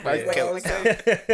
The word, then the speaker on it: Mykel: No, some people say Michael, Mykel, like all different, but yeah. Mykel. That's just how I pronounce. I don't even know if that's the correct pronunciation, but that's right.